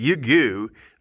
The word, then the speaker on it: Ryugu: That's how you pronounce it, Yugu, another asteroid. But Yugu is spelled R-Y-U-G-U.